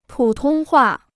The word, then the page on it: 普通话: pǔ tōng huà